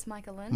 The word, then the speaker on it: Micahlan: It's Micahlan.